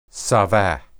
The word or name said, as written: saba